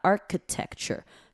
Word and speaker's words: architecture: 第一个单字叫做 architecture， architecture，刚刚有讲哦，这个 CHI 这边的那个 I 哦，就不用特别发音，就把它 architecture 就是 k 过去就可以了。